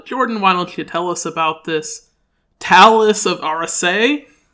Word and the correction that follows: Tales of Arise: Am I pronouncing that right? – Close. I have been playing Tales of Arise,